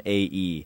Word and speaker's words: Stromae: His name's Stromae, and it's Stromae.